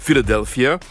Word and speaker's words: Philadelphia: c'est une contrepétrie sur le nom de leur ville qu'on prononce en anglais Philadelphia.